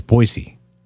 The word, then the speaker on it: Boise: It's Boise. – Boise. – Yeah. You got to say it right. Yeah, say it like a local.